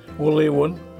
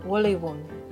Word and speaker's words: Wulewun: How do you say thank you? – Wulewun. – Wulewun. – Yeah, Wulewun.